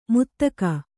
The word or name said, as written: ಮುತ್ತಗ